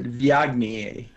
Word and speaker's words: Viognier: It's spelled V-I-O-G-N-I-E-R, and I have a friend who still wants to pronounce it Viognier. So here's a little trick you can use to order. Just tell yourself, just say Viognier.